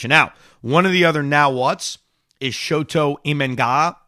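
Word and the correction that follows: Shota Imanaga: Let me pronounce his name right. Shota Imenaga, who is another Japanese pitcher.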